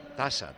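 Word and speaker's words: Taça: Benfica, Braga, Taca, Portugal. – Tasa, sale, no Taca.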